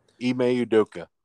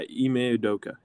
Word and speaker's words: Ime Udoka: I can't pronounce his name. It kills me every time. – Ime Udoka. – Yeah, Ime Udoka. Yeah. So. – Well, that's how they all pronounce it on ESPN.